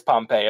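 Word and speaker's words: Pompeji: I'm pronouncing it as Pompeii. It's not spelled exactly the same as the famous city, but we're just going to call it that.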